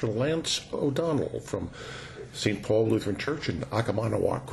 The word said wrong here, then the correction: Oconomowoc: You stress the second syllable. It's Oconomowoc. – Oconomowoc.